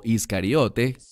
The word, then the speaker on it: Iscariote: Se trata de Judas Iscariot, o Iscariote, como normalmente se pronuncia en español.